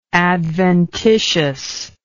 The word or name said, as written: adventitiousness